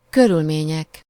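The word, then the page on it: körülmények: IPA: /ˈkørylmeːɲɛk/; Hungarian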